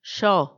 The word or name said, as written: xo